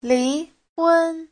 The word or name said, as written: li2hun1